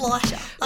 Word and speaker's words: Schleicher: Like it's just Schleicher. Like it's spelt more difficult than it actually is, but people butcher it.